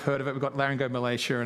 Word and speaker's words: laryngomalacia: So, for example, just to take some of the stories Shelby and I have shared, like the story of Lucas with his laryngomalacia.